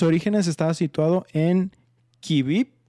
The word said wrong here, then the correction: Kiev: Kivip, Ucrania, no sé cómo se pronuncia, es K-Y-I-V. – Kiev. – Kiev.